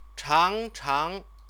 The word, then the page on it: changchang: Chinese